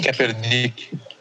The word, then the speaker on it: Kaepernick: Como é que fala o sobrenome dele? Colin Ka Ka Kaepernick. – Kaepernick é, é, a resposta da sociedade em relação a isso.